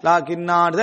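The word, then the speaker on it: லாகின்னா (لكنا): ஆனா நிறுத்தினமண்டா லாகின்னா அப்படின்னு நிறுத்தி நிறுத்துற இடங்கள் வருமே, நம்மளா நிறுத்திடுவோம் அந்த இடத்துல. நிறுத்திட்டோம்னா லாகின்னான்னு தான் என்ன செய்யணும், நிறுத்தணும்.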